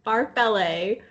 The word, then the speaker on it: farfalle: Because in like Italian and Spanish, you like say the L-E, farfalle.